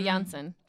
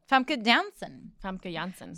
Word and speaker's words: Famke Janssen: That's Famke Janssen. – Famke Janssen. So her accent also slips in and out. – Just told us how to pronounce it.